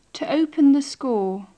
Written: to open the score

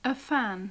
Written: a fan

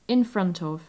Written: in front of

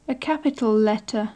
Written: a capital letter

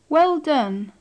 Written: well done

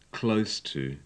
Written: close to

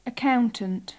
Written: accountant

